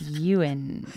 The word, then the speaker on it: Ewan: It's Ewan.